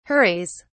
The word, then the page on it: hurries: British English